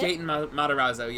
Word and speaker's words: Gaten Matarazzo: Gaten Matarazzo as Chip. – Gaten Matarazzo, yes.